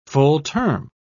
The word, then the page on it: full-term: British English